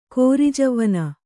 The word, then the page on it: ಕಿರುಜವ್ವನೆ: kirujavvane